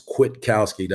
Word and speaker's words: Kwiatkowski: I, I guess we call him Kwiatkowski is what I everybody's been saying is Kwiatkowski. That's how supposed to be pronounced so.